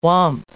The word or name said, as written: whomp